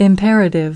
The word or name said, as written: imperative